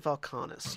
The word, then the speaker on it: Vulcanus: Vulcanus actually rhymes with... – Yeah, apparatus. We'll call it an apparatus of Vulcanus.